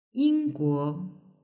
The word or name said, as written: yingguo